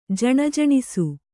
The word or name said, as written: ಝಣಝಣಿಸು